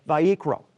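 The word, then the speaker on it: Vayikra (ויקרא): We actually pronounce it in Hebrew as Vayikra.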